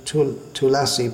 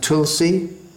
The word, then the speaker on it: Tulasi: Now the word Tulasi, going on to Tulasi prayers, in Sanskrit it's pronounced Tulasi, in Bengali, well, in Hindi it's pronounced Tulsi, or in Bengali Tulshi, but that's all right when we're singing the Bengali song, but in the Sanskrit prayer that we chant, first of all, it should be Tulasi.